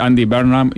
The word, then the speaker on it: Andy Burnham: Yo voy a decir Andy Burnham y vos le vas a decir de otra manera, pero no importa.